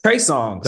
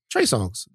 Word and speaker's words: Trey Songz: Trey Songz.